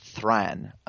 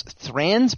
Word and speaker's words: Thrain: So the very first question regards Thorin's grandfather, or not grandfather, father, Thran, or Thrain. Every single time I say his name, I say it differently. It's T-H-R-A-I-N. – Thrain sounds very dwarvish to me. – Yes, it does. – Thrain. – The only doubt I have is that Corey seems to say Thran a lot, so it might be Thran or something that's sort of halfway, vowel sound.